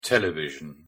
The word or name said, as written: television